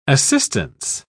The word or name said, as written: assistance